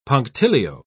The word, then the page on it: punctilio: British English